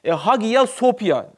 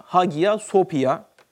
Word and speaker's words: Hagia Sophia: Tabi Ayasofya olarak telaffuz olarak değil de yani onların dilinde Hagia Sophia yani yazılışı o şekilde Hagia Sophia diye öyle telaffuz ediyormuş.